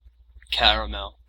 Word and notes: caramel: American English

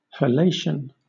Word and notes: halation: British English

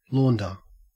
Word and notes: launder: Australian English

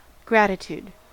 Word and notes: gratitude: American English

